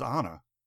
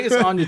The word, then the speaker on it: Anya: I thought it was Anna. – I was going to Anna. So I think it's Anya Taylor-Joy.